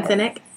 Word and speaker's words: Sinek: Sinek. How do you say his name? – Sinek. – Sinek.